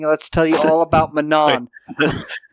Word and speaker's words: Manon: Manon. It's like definitely a French pronunciation, the kind of nasally and sound.